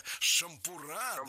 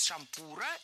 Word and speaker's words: шампура: Действительно, с шампура неверно, а вот с шампура это литературная норма. Во всех формах нашего слова мы сохраняем ударение на втором слоге. Шампура, шампуру, шампуром и во множественном числе тоже. Шампуры, шампуров, шампурами. Следующий пример я нашла в программе «Хорошо темперированный эфир».